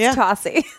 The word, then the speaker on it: Tosi: It's Tosi. – Is it Tosi? – Yes. – Yes. I want to make a long O for some reason.